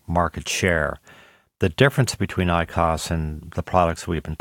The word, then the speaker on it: IQOS: It is called IQOS, I-Q-O-S, am I saying that properly?